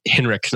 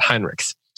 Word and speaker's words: Heinrichs: I said it wrong last week and I was corrected, so I'm trying to get it right. Heinrichs, not Heinrichs.